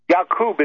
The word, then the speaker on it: Yaqub: Yaqub is the proper pronunciation of what we say is Jacob.